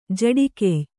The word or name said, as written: ಜಡಿಕೆಯ್